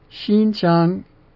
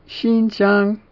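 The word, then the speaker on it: Xinjiang (新疆): Xinjiang, Xinjiang, Xinjiang, Xinjiang is north of the Kunlun Mountains.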